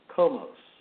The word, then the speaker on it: Komos: He talks about koimos, the god of partying, or Komos, or it K-O-M-O-S, or it is spelled C-O-M-U-S, the god of partying.